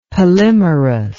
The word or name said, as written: polymerous